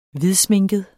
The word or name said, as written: hvidsminket